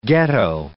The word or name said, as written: ghetto